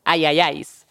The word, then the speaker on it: IIIS: And the name of the group, it's spelled I I I S, but how do you pronounce it? – Ayayays. All right.